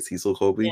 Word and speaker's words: Cecil Colby: So yeah, there's a scene where she's playing pool with Cecil. Am I saying that right? Cecil Colby.